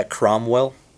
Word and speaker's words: Cromwell: Yeah, Cromwell.